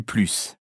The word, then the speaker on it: plus: Plus ou plus? En français courant, on prononce généralement le S. – À pied, je mets plus de temps. Ça pollue plus.